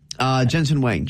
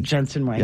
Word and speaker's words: Jensen Huang: Jensen Huang. – Jensen Huang. Yep. What Jensen does not, Huang does not look like it's spelled, by the way.